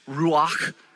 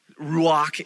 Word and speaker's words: ruach: It's ruach. Ruach. If you don't spit a little bit, you're not saying it right. It's ruach. Now, ruach is this.